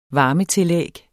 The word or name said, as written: varmetillæg